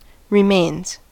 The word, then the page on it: remains: American English